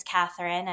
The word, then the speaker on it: Kathryn: But my name is Kathryn, as you all know, with a K and an R-Y-N.